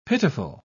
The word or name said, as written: pitiful